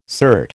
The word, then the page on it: cert: American English